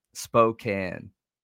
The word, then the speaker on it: Spokane: Spokane.